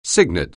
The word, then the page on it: cygnet: anglais